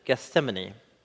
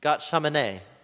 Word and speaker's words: Gethsemane: It's hard to say in English, isn't it? Gethsemane. It's easier to say in Hebrew than it is in Gat Shamane is how you say it in Hebrew. Gethsemane. Something about the THS. Anyway, Gethsemane.